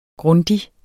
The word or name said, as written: Grundig